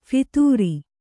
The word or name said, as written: ಫಿತೂರಿ